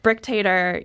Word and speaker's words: Brick Tater: I mean, Brick Tater, you know, her name rhymes with dictator, and that's who she is on the team.